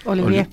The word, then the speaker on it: Olivier: Y hay que decir que la ensalada Olivier, o no sé si se pronuncia así, Sí, Olivier.